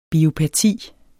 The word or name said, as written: biopati